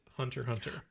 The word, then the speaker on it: Hunter x Hunter: Stylizes Hunter x Hunter, pronounced Hunter Hunter. – Boom. – Good. I didn't say you were wrong once again. Oh, because the X is a little X.